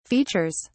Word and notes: features: British English

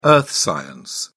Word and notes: earth science: British English

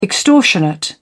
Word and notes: extortionate: British English

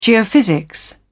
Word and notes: geophysics: British English

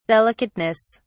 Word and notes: delicateness: British English

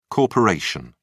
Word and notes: corp: British English

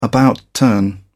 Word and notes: about turn: British English

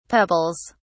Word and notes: pebbles: British English

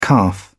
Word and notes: calf: British English

